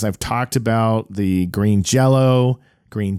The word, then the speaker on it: Jello: But with the accenting, it's still pronounced jello.